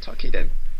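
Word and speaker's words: Toukiden: Now this is where I'm going to get slapped for saying it wrong. Tokiden? – Correct. You said it right, Tokiden.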